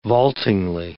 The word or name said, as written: vaultingly